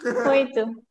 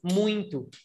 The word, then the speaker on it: muito: Muito. – Muito. But everybody pronounces like this. Muito. – Yeah, muito. An M, muito. A silent M inside of it.